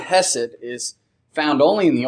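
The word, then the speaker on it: chesed: This word loving kindness is a translation of the Hebrew word hesed, and it's actually pronounced with a hard C, and you'll see it spelled sometimes in different texts and in different tools that we use looking at the Hebrew language with a C, C-H-E-S-E-D. And the pronouncing of the word is something that I'm not going to do correctly this morning because I'm American, I'm not Hebrew.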